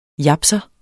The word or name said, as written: japser